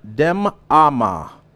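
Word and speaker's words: demamah: The word still is a Hebrew word, now that we're in the Old Testament portion of the Bible, and it is a word pronounced dem ama.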